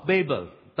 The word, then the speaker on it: Babel: The Tower of Babel. That's how we pronounce it in India.